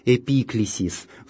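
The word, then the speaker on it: эпиклесис (ἐπίκλησις): Следующая смысловая часть евхаристической молитвы – «эпиклезис». Это, опять же, греческое слово, которое не в латинизированном виде по-гречески произносится как «эпиклесис».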